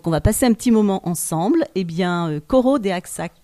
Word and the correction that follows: Choro des Axac: peut-être je le prononce mal, ouais. Et Mathias va me corriger et nous expliquer qu'est-ce que cela signifie. – Tout à fait, bonjour à tous. Alors c'est Choro des Axac, exactement.